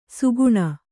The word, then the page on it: ಸುಗುಣ: suguṇa; Kannada